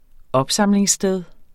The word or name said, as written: opsamlingssted